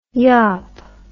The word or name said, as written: yop